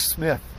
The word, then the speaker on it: Smith: Except I don't Mark Smith, kind of a name is that? Rhymes with myth.